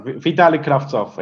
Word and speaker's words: Vitali Kravtsov: First off, did I say his name correctly? Probably not. – It was closer than your previous attempt. So, Vitali Kravtsov is pretty close.